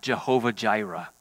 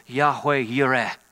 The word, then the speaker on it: Jehovah Jireh: We say it with a J sound, Jehovah Jireh. Jehovah Jireh, or Yahweh Yireh, is maybe a little bit more of the modern pronunciation of how to say it, but the root of the word is seeing.